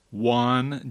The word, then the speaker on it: Juan: Spoonerize that, and you get Juan Jane, both starting with J, but those J's are pronounced differently.